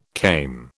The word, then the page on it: came: British English